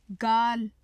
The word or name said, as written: gaal